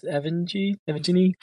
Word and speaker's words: Evgeny: How do you pronounce Evgeny? – Evgeny is the correct one.